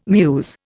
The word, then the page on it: mews: Indian